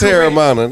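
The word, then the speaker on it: Teremana: Terramana. – That's probably not right, but. – It is Terramana, nigga.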